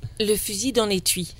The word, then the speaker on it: Le fusil dans l'étui: Vamos a escuchar el tema, ¿cómo se pronuncia esto? ¿Le fusil? – Le fusil dans l'étui.